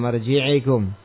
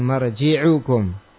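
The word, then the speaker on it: marji'ukum (مرجعكم): sehingga ke bawah gitu tetangganya marji'ikum bukan marji'ukum. Fain bagi tafattun lidzalik, maka harus dicermati ya demikian, karena ini adalah kesalahan pengucapan.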